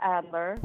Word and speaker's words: Adler: Hey, on some of your training, so it says the word Stella Alders. Did you actually work with Stella? I'm saying her name right? – Stella Adler.